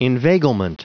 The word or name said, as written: inveiglement